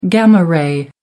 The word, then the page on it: gamma ray: American English